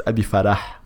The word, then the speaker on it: Abi Farah: Okay, though in Arabic, it's Abi Farah. – Yeah. – Because the H is pronounced.